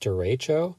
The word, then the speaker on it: derecho: It's actually pronounced derecho.